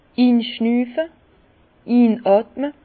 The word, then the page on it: inschnüfe, inotme: Bas-Rhin; Herrlisheim